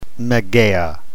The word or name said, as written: mageia